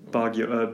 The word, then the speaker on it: Baguio: Baguio, B-A-G. – B-A-G? – U-I-O. – U-I-O, there you go, okay.